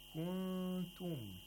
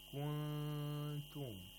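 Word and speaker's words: Kuntum (كُنْتُم): Kuntum. We have a nun sakina followed by a ta. And so we have to pronounce it with ikhfa. The nun has to be pronounced with ikhfa. You have to apply ikhfa. Kuntum. You approximate the place of articulation of the ta. And at the same time, you make a nasal sound for two vowel counts.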